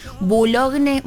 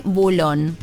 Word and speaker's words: Boulogne: Otras localidades que se pronuncian diferente, Boulogne, Boulon.